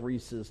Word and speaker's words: Rices: Did you know that Reese's, the man, his last name was not spelled like we have Reese's today. It was actually spelled R-I-C-E-S. But it was not pronounced Rice's, it was pronounced Reese's.